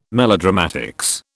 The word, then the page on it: melodramatics: British English